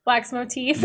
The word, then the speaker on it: Wax Motif: You know who I like is Max Votive. I totally said that wrong. Wax Motive. – Wax Motif. – Motif. See, I totally. At first I said Max.